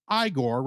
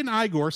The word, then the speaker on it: Igor: Hearing that the professor pronounces his name Frankenstein, Igor insists that his name is pronounced Igor rather than the traditional Igor.